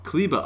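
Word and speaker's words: Kleber: And I believe, what's the correct pronunciation of his last name? – Kleba. – Okay, so Matt Devlin was right. Matt Devlin was really on the Maxi Kleba, and I thought it was like an unnecessary overpronunciation.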